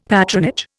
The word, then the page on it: patronage: Indian